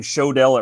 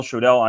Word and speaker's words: Schodel: My last name Schodel. It rhymes. Miguel Schodel.